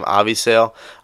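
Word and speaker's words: Avisaíl: Avisail Garcia, I believe is how you say his first name, Avisail.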